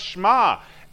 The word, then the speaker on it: Shema: But she said, no, it's pronounced Shema.